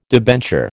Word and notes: debenture: American English

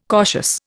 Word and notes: cautious: Indian

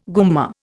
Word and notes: gumma: Indian

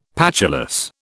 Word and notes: patulous: British English

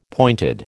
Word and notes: pointed: American English

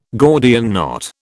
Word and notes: Gordian knot: British English

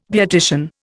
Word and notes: beautician: Indian

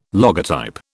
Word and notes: logotype: British English

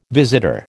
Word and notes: visitor: American English

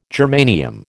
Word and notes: germanium: American English

